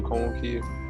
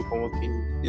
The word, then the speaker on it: Ukanwoke: So if you want to reach me, Emmanuel Okonwoke, Facebook, Emmanuel Okonwoke, Instagram, same thing as LinkedIn as well. For people who may not be able to spell it, Okonwoke is spelled UKANWOKE.